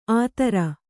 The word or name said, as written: ಆತರ